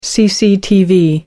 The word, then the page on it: cctv: American English